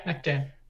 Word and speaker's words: Mech Jam: Yeah, Mech Jam.